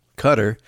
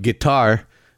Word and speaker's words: Qatar: It's coming from not Qatar, but Qatar. You remember that back in the 9/11 days. Oh, no, it's not Qatar, it's Qatar. That's the proper pronunciation.